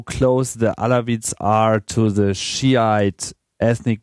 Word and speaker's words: Alawites: The Syrian government, mostly Alawites, how do you say that? Alawites? – Yeah, I say Alawite, yeah.